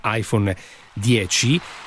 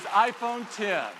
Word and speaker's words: iPhone X: Noi adesso ci concentriamo su due temi, perché introduciamo il nuovo telefono che si chiama appunto per alcuni iPhone X, perché si scrive così, quindi uno dice iPhone X, ma in realtà si pronuncia iPhone X, cioè iPhone 10. – This is iPhone 10.